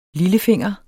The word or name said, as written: lillefinger